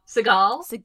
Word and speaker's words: Seagal: I took this test and I allegedly got, how do you say it, Seagal? – Seagal.